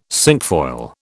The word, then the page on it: cinquefoil: British English